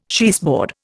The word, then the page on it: cheeseboard: Indian